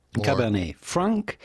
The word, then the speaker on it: Cabernet Franc: Cabernet Franc, Cabernet Franc, but the T at the end of Cabernet is still silent, Cabernet Franc. But for the typical French pronunciation, you do not want to pronounce the C at the end of Franc because it is silent in French, as many letters, last letters of words are just like the T of Cabernet.